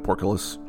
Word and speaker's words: portcullis: As the group makes their way down the ramp, they notice that there is no mechanism to open the portcullis.